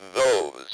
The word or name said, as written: those